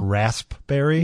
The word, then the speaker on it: raspberry: Raspberry? – Yeah. Do you pronounce the P in it when Never. – You say it? I haven't either.